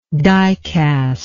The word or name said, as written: dicastic